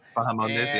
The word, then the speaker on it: Bahamondes: How do you say his name? Ignacio. – Ignacio. Yes. Bahamondes, yes.